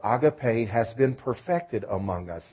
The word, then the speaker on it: agape: The highest form of love is the word agape, or more commonly pronounced agape.